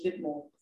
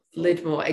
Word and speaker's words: Lydmor: More popularly known as Lydmor, but actually outside of Denmark, I always go with Lydmor. – Lydmor, exactly. Yeah. That was going to be my English pronunciation of it, Lydmor. – Yeah. Ugh. I like Lydmor. It sounds like a little more.